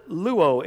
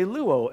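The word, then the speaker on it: luo (λύω): It actually means, it's the Greek word luo. It's spelled L-Y-O, but you say luo.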